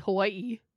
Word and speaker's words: Hawaii: Everybody bugs me for how I say like Hawaii, but I say it properly. Hawaii. – Hawaii. – Yeah, that's the... Oh, I see. That's the proper way to say. – Yeah, because there's the double I on the end, so it's Hawaii.